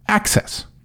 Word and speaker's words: AHCCCS: Som till exempel här i Arizona så kallas programmet Arizona Healthcare Cost Containment System, eller AHCCCS, vilket uttalas Access.